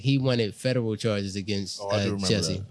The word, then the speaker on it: Jussie: I mean, Jussie. – Jussie, you said it right.